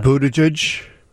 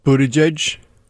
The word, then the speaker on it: Buttigieg: Okay, so at least they're all soft G's. It's J and J, Buttigieg, Buttigieg, Buttigieg.